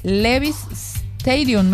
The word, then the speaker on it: Levi's Stadium: La concentración de ayer y se confirma que el sábado a las 0 horas va a ser el partido en el Levi's Stadium, me habías dicho que se pronuncia así.